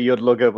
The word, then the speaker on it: Yudlugar: Yeah, I say Yudlugar, but you can say it how you want.